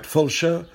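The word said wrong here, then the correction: Fowlsheugh: Foulshugh. Foulshugh.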